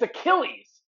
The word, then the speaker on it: Achilles: Iggly heel. – How do you say it again? – It's Achilles. – It's the way it's spelled. It's spelled like A is Chili's.